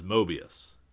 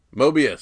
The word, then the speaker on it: Mobius: there is no R in that. I don't know how you're pronouncing that, but that is Mobius. Mobius, sorry.